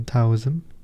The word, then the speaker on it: Taoism: And this comes from Taoism. I think I read it not long ago in one of Alan Watts's book on Taoism, or Taoism. It's spelled with a T but pronounced as a D.